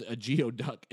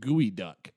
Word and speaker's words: geoduck: sorry, it's not even pronounced a geoduck. It's pronounced gooey duck.